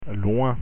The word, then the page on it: loin: French